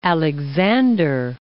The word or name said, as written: Alexander Archipelago